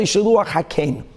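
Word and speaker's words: Shiluach HaKen: or otherwise known as Shiluach Hakan, or perhaps pronounced correctly, Shiluach Hakein.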